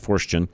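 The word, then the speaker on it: Forstchen: And it's F-O-R-S-T-C-H-E-N, Fortune is how I should pronounce that, Fortune.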